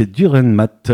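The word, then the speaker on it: Dürrenmatt: On ira même le 29 avril à 20h pour aller voir La Panne, une pièce de Frédéric Durenmat.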